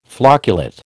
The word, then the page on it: flocculate: American English